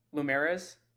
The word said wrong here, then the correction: Lumryz: Loomrise. – How did you say it? – Loomrise. – Loomrise. Much better than what I said.